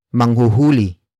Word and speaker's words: manghuhuli: Word for word it's manghuhuli, tayo, nang, manok, mamaya.